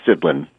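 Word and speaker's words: Sidlin: Murray, have I totally butchered your last name or no? – Pretty well, yes. Thank you. – No, should I did okay? What's the correct pronunciation? – It's Sidlean.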